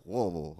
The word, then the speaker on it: Louisville: He's at Louisville right now. Louisville, sorry, excuse me.